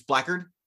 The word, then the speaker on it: Blackguard: So whenever you have a situation like this, this is an old like D&D routine, is like it's spelled Blackguard, but it's Blackguard.